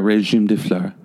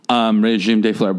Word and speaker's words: Régime des Fleurs: Régime des Fleurs, but I don't say Régime des Fleurs. – Yeah, that'd be too much. – You don't pronounce the S's.